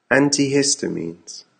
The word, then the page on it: antihistamines: American English